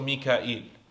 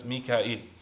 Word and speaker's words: Mika'il: And Mikael is one way that it's pronounced. It's also Mika'il, right? In a different qira'ah, you recite it as Mika'il.